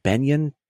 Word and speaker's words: Beynon: I will say, note from Cody in the chat, it is spelled B-E-Y-N-O-N, and that confused me as well. In the captions, I watched this movie at home. The captions, I believe, was Amazon Prime. Mm-hmm. Everybody was saying Benyon, but Banyan is how it's spelled.